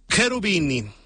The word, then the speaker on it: Cherubini: Penso positivo del italiano Jovanotti, cuyo nombre real es Lorenzo Cherubini. Es que acuérdense que la CH en italiano se pronuncia como K. Lorenzo Cherubini.